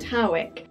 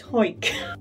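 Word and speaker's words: Hawick: Now, some people pronounce it Howick, but a quick Google has taught me that people from Hoik tend to call it Hoik.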